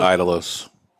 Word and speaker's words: Idolos: I'm going with Idolos. Because it starts with an I. It doesn't start with an E.